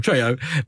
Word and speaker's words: Trejo: Trejo.